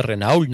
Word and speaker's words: Renault: Cuando oigan algo parecido es Renault, hombre, que digo para aclararlo para los españoles. Sí, sí, se dice Renault, ¿no? – Renault.